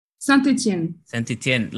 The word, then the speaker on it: Saint-Étienne: Saint-Étienne.